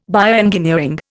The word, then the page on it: bioengineering: Indian